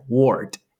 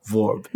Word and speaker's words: word: 他不是讲 verb，他是讲 word。可是你知道德国、oh. 德文发音，德文发音的 w 就是发 v 的音，所以呢，他他讲 word，他会念成类似这样 verb，嗯，这样。